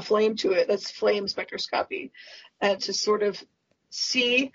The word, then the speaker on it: spectroscopy: Spectroscopy.